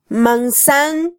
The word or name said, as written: mansan